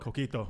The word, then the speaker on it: Coquito: Coquito.